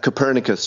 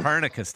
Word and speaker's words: Copernicus: Copernicus, Shane. – Copernicus, thank you.